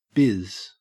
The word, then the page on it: biz: Australian English